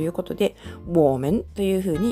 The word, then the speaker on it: 我们: ウォーメン我们。